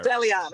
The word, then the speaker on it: Celiane: Did I pronounce, I feel like I just pronounced that. – Celiane, it's fine.